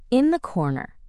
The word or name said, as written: in the corner